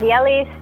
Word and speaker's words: Yelly: Yes, Yelly Staley, without the N.